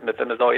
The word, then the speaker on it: methimazole: Okay, now conventional treatment for this is typically, I've read about like anti-thyroid drugs like the, how do you pronounce it, the methimazole, and then... – Yep, methimazole, yep.